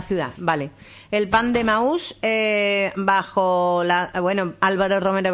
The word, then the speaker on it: Emaús: Tiene tilde en la U. – Emaús, por eso he dicho, ¿no? Digo, si tiene tilde, se dirá Emaús, era una figura bíblica.